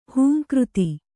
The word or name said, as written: ಹುಂಕೃತಿ